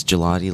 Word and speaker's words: Gigliotti: Well, it's Gigliotti like the ice cream.